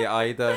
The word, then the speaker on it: Ida: Yeah, like however much I said hopeful, So that was Ida. – I was waiting for you to say Ida in the beginning.